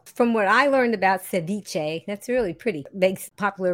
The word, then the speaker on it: ceviche: Now tell me, if I want to pronounce it correctly, how do we pronounce the word that's in the name of the dish that you're making? – So just ceviche.